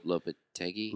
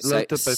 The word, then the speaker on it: Lopetegui: Although I'm interested, which this is the Lotetapengi. I can't say his name. Maybe we should have a how to say his name. Lopetegui. – Lopetegui. – That sounds right. – Lopetegui.